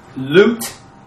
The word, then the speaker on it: Lut: And, And this has got a dot under it. Oh, long U. Lut.